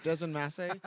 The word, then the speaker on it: Desmond Mase: Valentino, it is great to be here, and you said it right. You said the last name right. Thank you very much. I've been called worse things, but Desmond Mase, you are on. You are 100% on.